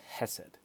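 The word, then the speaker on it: hesed (חסד): That word steadfast love in the Hebrew, it's the word hesed. That's how it's said in Hebrew. Probably could pronounce it better, but that's the word.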